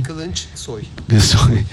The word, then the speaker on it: Kılıçsoy: Semih No, no, ma come si pronuncia? Kılıçsoy? – Kılıçsoy.